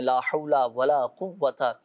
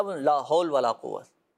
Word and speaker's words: لا حول ولا قوۃ: اچھا یہ بھی ہوتا ہے, صحیح تلفظ تو یہی ہے نا, لا حول. اچھا بعض اوقات لوگ کیا لا حول ولا قوت, اب یہ اس انداز سے پڑھیں گے تو پھر تو وہ فوائد حاصل نہیں ہو سکیں گے نا, تو صحیح تلفظ کے ساتھ ہی اس کو پڑھا جائے.